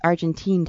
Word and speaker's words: Argentine: And, you know, I thought I'd start by asking you, Stephen, how long have you been doing Argentinian tango? – I've been dancing Argentinian tango for about 8 years now. – Oh, good. So I'm mispronouncing it. It's Argentine tango. Or is it Argentine? – Right.